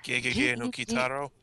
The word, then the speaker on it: Gegege no Kitaro: Gegege no Kitaro? – Yeah, Gegege? – Yeah, because it's... Yeah, because the E's are pronounced like a long A sound.